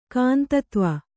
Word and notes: ಕಾಂತತ್ವ: kāntatva; Kannada

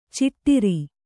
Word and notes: ಚಿಟ್ಟಿರಿ: ciṭṭiri

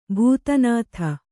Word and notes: ಬಿದಾನದ: Kannada

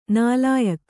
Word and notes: ನಾಲಾಯಖು: nālāyakhu

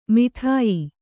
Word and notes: ಮೌಢ್ಯ: mauḍhya

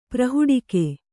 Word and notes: ಪ್ರಹುಡಿಕೆ: Kannada

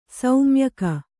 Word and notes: ಶಮ್ಯಾಕ: kannada

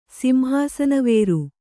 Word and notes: ಸಿಂಹಾಸನವೇರು: kannada

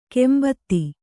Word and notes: ಕಾಂಬೋದಿ: Kannada

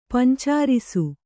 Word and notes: ಪಂಚರಸ: Kannada

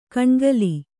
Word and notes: ಕಣ್ಗಲಿ: kaṇgali

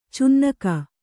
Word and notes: ಚೆನ್ನಿಗ: cenniga

